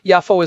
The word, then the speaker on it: Yafo: Yafo is closer to the modern Hebrew pronunciation and follows a more academic transliteration convention.